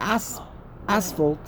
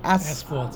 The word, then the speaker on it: asphalt: Asphalt. – Say it again. How do you pronounce it? Asphalt. – Asphalt. – PH? – Yeah.